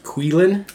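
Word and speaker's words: Qilin: In Chinese, it's actually pronounced Quilin.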